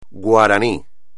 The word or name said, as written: guaraní